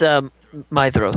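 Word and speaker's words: Maedhros: It's Mydros. – That's right. D-H is a Mydros. T-H. Mydros.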